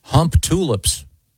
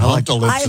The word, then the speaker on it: Humptulips: Boy, Humptalips is almost more offensive. – Yeah. – I like it. – Yeah. But yeah, it's Humptulips.